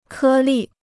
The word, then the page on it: 颗粒: kē lì